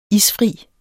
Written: ISFRIT

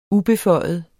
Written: ubeføjet

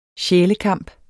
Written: sjælekamp

